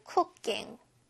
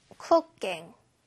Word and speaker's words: cooking: cooking 아니고요. 쌍기역 발음 cooking. cooking.